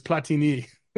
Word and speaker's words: Platini: And my friend Sean, who knows soccer much better, told me it's Platini. Mm-hmm. And it makes a difference how you say it, right?